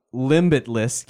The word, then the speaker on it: Limbitless: The group out of University of Central Florida that's been doing a lot of this work with an organization called Limbitless. Get it? Limitless, but there's kind of like a sort of silent B in there.